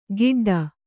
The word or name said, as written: ಗಿಡ್ಡ